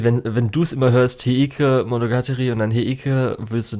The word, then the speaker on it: Heike Monogatari: Es ist Heike Monogatari.